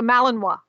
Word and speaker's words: Malinois: It's a Malinois. And that's how you pronounce it, Liz. I even looked it up.